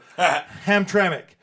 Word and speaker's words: Hamtramck: First, I want to thank Johnny for pointing out, Hamtramck, because I was pronouncing that that city incorrect. So Hamtramck. On the 15th, they'll be playing in Hamtramck at New Dodge Lounge with Keep Away, Consencia Total from Chicago, and Disturbio Social.